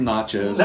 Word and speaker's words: Natchez: It's pronounced Natchez.